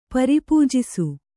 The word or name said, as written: ಪರಿಪೂಜಿಸು